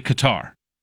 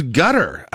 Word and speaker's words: Qatar: America's speakers say Qatar with stress on the second syllable. British English speakers add an A to sound more equivalent to cat and give more equal weight to this two syllables.